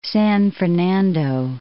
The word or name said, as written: San Fernando